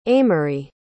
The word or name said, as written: aimery